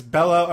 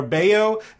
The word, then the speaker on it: Bello: It's B-R-A-Y-A-N for Brian. It's B-R-A-Y-A-N. It's Bello or Bayo. It's B-E-L-L-O.